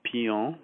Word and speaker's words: Pignon: Pion, which is spelled P-I-G-N-O-N,